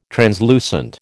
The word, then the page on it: translucent: American English